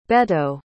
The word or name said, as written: Beto